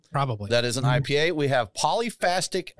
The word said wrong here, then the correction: Polyphasic: Echo. Is did I pronounce that one correctly? It's uh it's polyphasic.